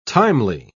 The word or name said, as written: timely